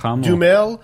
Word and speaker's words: Duhamel: Star Shia LaBeouf, Rosie Huntington-Whiteley, Josh Duhamel. How do you pronounce his last name? Anybody know? – Duhamel.